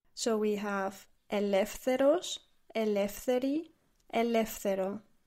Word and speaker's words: ελεύθερος: So the word for free is ελεύθερος, and it's an adjective. – It's a long word. – Let's break it down into syllables. Ελεύθερος.